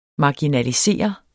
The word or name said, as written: marginalisere